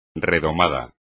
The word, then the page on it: redomado: espagnol